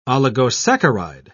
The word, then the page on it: oligosaccharide: British English